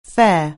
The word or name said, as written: fare